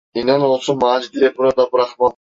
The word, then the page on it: burada: turkish